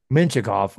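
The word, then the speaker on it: Mintyukov: I was like, I feel like I remember seeing a quote about how Pavel Mintikov, or how do you say his name again? – Minchikov.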